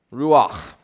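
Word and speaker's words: ruach (רוּחַ): The Hebrew word for spirit is ruach. Now, I'm not great with foreign pronunciations. Well, I'll try. It's got that guttural ch at the end of it.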